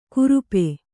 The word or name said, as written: ಕುರುಪೆ